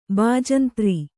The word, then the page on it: ಬಜಂತ್ರಿ: bajantri